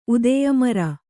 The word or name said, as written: ಉದೆಯಮರ